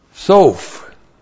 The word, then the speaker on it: Soph: We have Soph. S-O, long O-P-H. Soph.